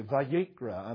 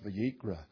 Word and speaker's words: Vayikra: And in the Hebrew language, those particular words are pronounced that way, Vayikra, and therefore they called it the Vayikra, with reference to how the book actually starts out.